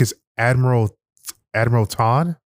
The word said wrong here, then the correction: Thrawn: or Thrawn, Admiral Thrawn. That's what it is.